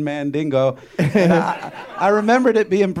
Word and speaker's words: Mandingo: It's pronounced Mandingo, first of all.